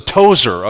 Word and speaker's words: Tozer: I get asked all the time, so Tozer, okay, that's our name is spelled T-O-Z-O-U-R, but it is the same family as A.W. Tozer and T-O-Z-E-R.